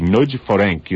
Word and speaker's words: Nagy Ferenc: It is pronounced, actually, Nagy Ferenc. You actually sort of slur the two words. You put the first last name first and slur them together, I'm told, by a colleague who works at another station who speaks Hungarian.